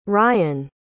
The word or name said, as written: ryun